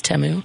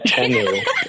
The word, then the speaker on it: Temu: was the one ad that I've been pronouncing Temu wrong the entire time. It's Temu. – Temu. Oh, I didn't know. I had no clue. – Oh, my bad. Temu.